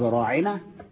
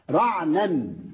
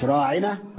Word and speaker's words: راعنا: القرآن نفسه أعطى المثل في الآية دي إذ قال وراعنا لين بألسنتهم وراعنا, فيغيرون منفوق اللفظ راعنا أي اصغي إلينا, راعنا يعني ارعانا رب خد بالك مننا, فهم يغيروها وليه فينطقون رعنا مش راعنا رعنا.